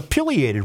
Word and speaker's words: pileated: Violated. – It was a pileated woodpecker. – Pileated. Thank you. – Drawn in 1940 for Universal Studios. – I have never, not once have I pronounced that correctly.